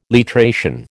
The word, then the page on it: literation: American English